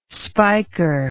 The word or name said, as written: spiker